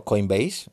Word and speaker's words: Coinbase: Yo uso eh, Coinbase, ¿vale? O Coinbase, si lo pronuncias bien en inglés.